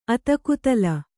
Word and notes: ಅತಲಕುತಲ: atalakutala; Kannada

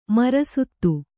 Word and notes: ಮರಸುತ್ತು: marasuttu

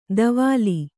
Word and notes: ಧವಲಿ: dhavali

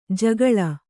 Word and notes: ಜಗುಲಿ: jaguli

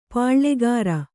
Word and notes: ಪಾಳ್ಳೆಗಾರ: pāḷḷegāra

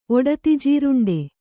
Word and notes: ಒಡತಿಜೀರುಂಡೆ: oḍatijīruṇḍe

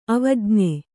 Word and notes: ಅವಜ್ಞೆ: avajñe

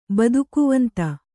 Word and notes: ಬದುಕುವಂತ: Kannada

